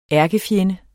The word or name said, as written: ærkefjende